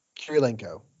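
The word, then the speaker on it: Kurylenko: Olga Kirilenko.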